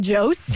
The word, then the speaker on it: Jost: Colin Jost? – Jost, is that it? Jost? – Yes. – Jost? Jost? – You just like deleted the J? – It's a silent J.